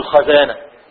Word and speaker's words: خزانة: خزانة، قل خزانة ولا تقل، لا تفتح الخزانة، الخزانة، لا تقل خزانة، ولا تكسر القصعة، لا تقل قصعة.